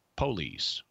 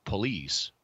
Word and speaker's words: police: Like, for example, you in some places, in some English accents, you'll hear police pronounced police, which stresses the first syllable, which is the one before the last. – Yeah. – But the in most places, at least here in America, where you hear that word pronounced, it's police, which puts the accents on the last syllable on the ultima.